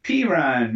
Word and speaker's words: Perine: It's like Samaje Perine or something. I can't even pronounce his name. – Perine, man. – Perine, yeah.